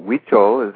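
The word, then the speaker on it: Huichol: healing camps based on traditional Hukou healing and animal totem poles. Am I pronouncing it correctly? – Wicho is how it's said.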